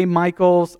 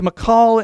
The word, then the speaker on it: Michal: And I know that some people say Michael's. I say Michal because I just bragged about Hebrew and I looked it up because I wasn't sure how to say it. But it's Michal, and it's weird to say, but that's her name.